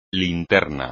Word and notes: linterna: Spanish

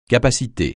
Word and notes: capacité: francais

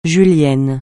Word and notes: julienne: francais

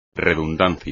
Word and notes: redundancia: espagnol

